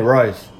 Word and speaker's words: Rice: What's your name, Rice? One syllable. They gotta get it. Like the food?